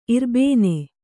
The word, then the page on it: ಇರ್ಬೊನ್: kannada